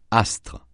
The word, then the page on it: astre: francais